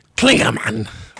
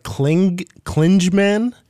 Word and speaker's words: Klingemann: I know it's German, so I'm gonna go with Klingemann. – Or something. – it's like Klingemann. K-L-I-N-G-E-M-A-N-N.